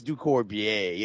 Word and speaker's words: Ducorbier: It's DuCourbier. It's DuCourbier. – It's French, like the dressing.